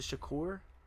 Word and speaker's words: Shakur: Yeah, there's a Tupac Shakur one if you see it right there. Shakur? Should I say Shakur? I meant Shakur. Is it Shakur? Shakur. Yeah, I said it right.